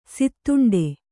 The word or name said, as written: ಸೀದೊಂಡೆ